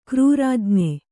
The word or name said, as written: ಕ್ರೂರಾಜ್ಞೆ